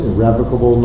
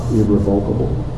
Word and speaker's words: irrevocable: Just to be a little pedantic, I want to point out that the word is pronounced irrevocable, not irrevocable. As an irrevocable fact, it's irrevocable.